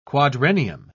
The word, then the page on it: quadrennium: British English